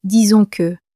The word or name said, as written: disons que